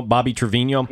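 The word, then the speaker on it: Trivigno: They're in their first final here, Zig, but the story breaking today, and am I saying his name correctly, Bobby Trevino? – Yes.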